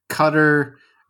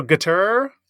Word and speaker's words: Qatar: Cutter, guitar. – From my understanding, the last syllable is like tur, or and then the Q is pronounced kind of between a K and a G. So guitar, cutter.